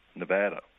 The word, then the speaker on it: Nevada: It's Nevada. And so that's how it's said. – So wait, you used to say what? – Nevada. – And now with a long A, like bath or glass.